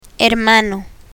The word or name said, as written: hermano